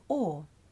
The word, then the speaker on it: Åre: Well, 2 years ago we went to the Norwegian town of Åre. It's spelt A, but pronounced Åre. – Åre? Just the vowel sound Åre?